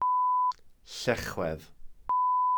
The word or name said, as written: llechwedd